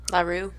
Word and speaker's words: Roux: It is called Automatic Driver by La Rue. Is that how you pronounce it? R-O-U-X. – La Rue.